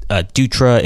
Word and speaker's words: Dutra: By the way, Dutra is spelled D-U-T-R-A. Again, that's D-U-T-R-A.